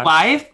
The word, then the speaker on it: Clive: Clive Burger? – Clive, like the name Clive, like rhymes with chive, Clive with an L.